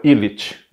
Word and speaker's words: Illicz: E se escreve Illicz, mas a pronúncia, salvo erro meu, é Illich, né?